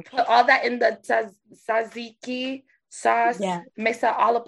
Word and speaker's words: tzatziki: I they usually put tzatziki, like the sauce. – How do you say it? – Tzatziki. – I've been saying it wrong. It's not tzatziki.